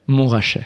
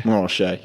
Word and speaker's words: Montrachet: Montrachet. – Montrachet.